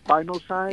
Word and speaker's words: spinosad: How do you pronounce that one more time? Spinosad? – Yeah, S-P-I-N-O-S-A-D, I believe is how it's spelled. – Spinosad, yeah. – Mm-hmm. Okay. – I think it's spinosad. – Okay. Spinosad. Oh, thank you. We had the emphasis on the wrong.